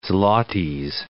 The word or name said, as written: zlotys